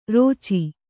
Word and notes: ರೋಚಿ: rōci

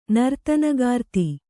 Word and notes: ನರ್ತನಗಾರ್ತಿ: kannada

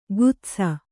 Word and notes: ಗುತ್ಸ: gutsa

